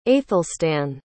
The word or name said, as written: aethelstan